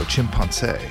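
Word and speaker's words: Chimpancé: Before that, it was Chimpanse. It's written like chimpanzee, except it's with the CE at the end, with the accent on the E. So Chimpanse.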